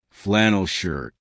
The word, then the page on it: flannel shirt: American English